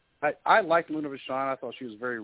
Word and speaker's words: Vachon: Luna Vachon? – Luna Vachon.